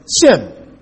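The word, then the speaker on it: Shem (שם): I mean, that's the way it's pronounced, Shem.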